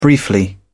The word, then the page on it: briefly: British English